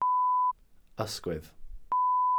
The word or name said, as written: ysgwydd